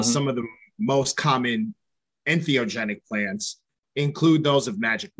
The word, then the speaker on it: entheogenic: the personal possession of and therapeutic use of ethnogenic plants. Did I say that correctly? – Entheogenic.